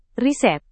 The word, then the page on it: riset: indonesian